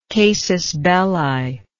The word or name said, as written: casus belli